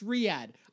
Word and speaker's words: Riyadh: It's Riyadh.